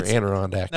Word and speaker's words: Adirondack: Is it Adirondack or Adirondack? – No, it's Adirondack.